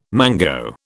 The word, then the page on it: mango: British English